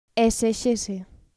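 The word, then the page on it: esexese: Galician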